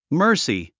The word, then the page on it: mercy: mə́ː r si; マースィ